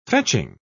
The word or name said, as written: fetching